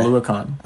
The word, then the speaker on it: Oluokun: I know they have Foyese Oluokun. – Say that again? – Foyese. – Yeah, that guy. – Oluokun. – Oluokun? All right.